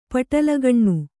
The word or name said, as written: ಪಟಲಗಣ್ಣು